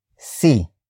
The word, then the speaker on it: si: And then we have C, which is spelled as S-I.